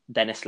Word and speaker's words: Denis: You can find me, Denis Lelin, again on LinkedIn um, to keep up with all of my updates.